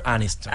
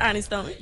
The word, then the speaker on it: Aniston: Jennifer Aniston. – Aniston.